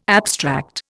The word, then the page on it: abstract: Indian